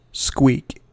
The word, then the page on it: squeak: American English